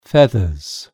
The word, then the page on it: feathers: British English